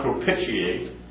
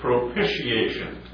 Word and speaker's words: propitiation: It looks, it's not propitiate. It's propish, like I-S-H. That's the way it's pronounced. I had a long time learning how to pronounce it. It's propitiation.